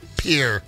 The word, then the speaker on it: Pierre: No one's calling it Pierre. It's spelled Pierre. It is Pierre. It was named after a French explorer, Pierre. But in South Dakota, it's Pierre. – It is Pierre, isn't it? Yeah, it don't sure you, is. Don't you dare throw an extra syllable in there. Pierre.